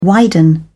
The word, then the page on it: widen: British English